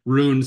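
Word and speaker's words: Rhûn: That is indeed a Lord of the Rings reference, referring to the land of the Easterlings, because it's spelled with the rune, spelled with the R-H-U-N, and the U has the little hat on top.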